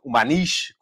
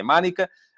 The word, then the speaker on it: Manica: Maniche, como se dizia em Portugal, mas a pronúncia correta é Manica, um, que uh, saiu ontem no F80.